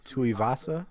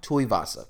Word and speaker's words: Tuivasa: How did you pronounce this gentleman's name? The Arlovsky's opponent. I don't want to mess his name up. – Ty Tuivasa. – Tuivasa, correct. Thank you.